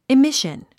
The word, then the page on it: emission: imíʃən; イミション